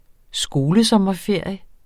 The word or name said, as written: skolesommerferie